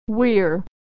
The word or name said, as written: we're